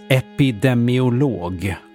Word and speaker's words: epidemiolog: Ordet uttalas epidemiolog och stavas även så, oavsett vad Lina påstår.